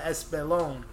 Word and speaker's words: Espolón: But he corrected T and was like, yo, you talking about Espolón.